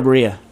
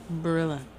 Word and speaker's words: Barilla: Is it pronounced Barilla or Berea? – Barilla. – Barilla? – Mm-hmm. – Like Gorilla? – Mm-hmm.